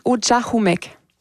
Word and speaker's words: Ujahumek: On le prononce Ujahumek. – Ujahumek.